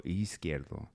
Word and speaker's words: Izquierdo: It starts with an I. In the Spanish, it's pronounced E. – So, Izquierdo. Uh-huh. There you go. Izquierdo.